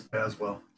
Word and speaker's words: Baswell: Yes, Baswell.